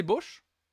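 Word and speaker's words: Bausch: Euh, non, c'est Bosch. C'est Bosch, du coup, ça se prononce C'est Pina Bosch du coup, ça se prononce, pardon, désolé, excusez-moi.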